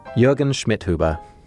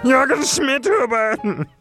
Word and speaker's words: Jürgen Schmidhuber: Jürgen Schmidhuber. – Jürgen Schmidhuber. – Okay. – What is that? Is that Dutch? – No, it's very German.